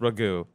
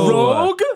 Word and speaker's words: Rogue: We were like, I think it was until we saw the X-Men cartoon that we're like, oh, it's not pronounced Ragu. Okay. – Ragu. – Rogue? – Yes. – Oh, Rogue. My God.